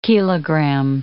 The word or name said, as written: kilogram